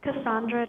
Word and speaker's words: Cassandra: Cassandra Drum, C-A-S-S-A-N-D-R-A, Drum, D-R-U-M-M.